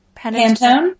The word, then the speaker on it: Pantone: Pantone.